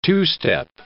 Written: two-step

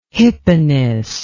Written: hypanis

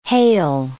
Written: hail

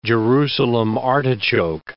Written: Jerusalem artichoke

